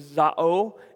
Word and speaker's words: zao: The word living is a, is a Greek word known as zao, is how it's pronounced in the Greek.